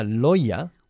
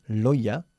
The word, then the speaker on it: lawyer: A lawyer. – Un abogado. – Fijaros en la pronunciación, a lawyer. No tiene nada que ver con cómo se escribe. A lawyer.